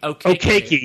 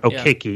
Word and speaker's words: Okeke: Okeke, yes. I know how to write it. I don't know how to say it. Okeke, yeah.